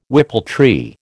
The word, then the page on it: whippletree: American English